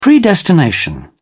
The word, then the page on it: predestination: British English